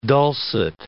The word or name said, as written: dulcet